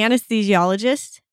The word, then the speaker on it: anesthesiologist: The anesthesiologist.